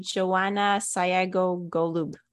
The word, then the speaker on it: Joanna Sayago Golub: Runner's World Meals on the Run, which doesn't really have one author because the recipes came from everywhere, but edited by Joanna Sayago Golub. Hopefully I'm saying that was a very good pronunciation.